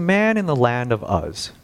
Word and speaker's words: Uz: So, but today's lesson is titled "The Father from Uz." It's not pronounced "Oz," as some people have said, or "Oz."